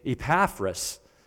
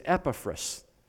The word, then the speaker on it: Epaphras: Now, if I sometimes accidentally switch to the name, I kind of grew up pronouncing this name Epaphras. That's another possible pronunciation, but probably the better Greek pronunciation is Epaphras.